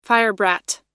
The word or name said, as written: firebrat